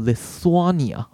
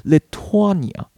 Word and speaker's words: Lithuania: 可是我去呃刚刚看了一下这个 YouTube 的影片，然后是一个立陶宛本地的一个专家在讨论这件事情，那我就发现，哎，他称呼自己的国家，他念法是念 Lithuania，所以这边 th 念作特哦，比较特殊哦，所以跟大家分享。那如果是立陶宛的啊，变形容词，或者讲立陶宛人怎么说呢？就后面加上那个 an。 n 的声音进来就好了，所以变成 Lithuanian，Lithuanian，OK，所以这边中国的外交部他们是说 Lithuanian side，